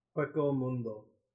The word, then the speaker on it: Hueco Mundo: And I think they knew that it was an interesting point to bring up, because they do the same thing with them going to Hinomungo, or whatever, how you pronounce it. – Hueco Mundo.